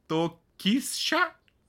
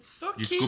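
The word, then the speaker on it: Tokischa: Toquisha, acho que é isso. – Toquisha. Desculpa. – Toquisha, falei certo desde o começo, tô me ah.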